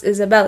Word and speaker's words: Isabelle: So my full name is Isabelle, but because it's spelled with an E in the end, like in the US, it's pronounced Isabel, but in Brazil, it's pronounced Isabelle.